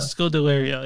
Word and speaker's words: Scodelario: Scodelario.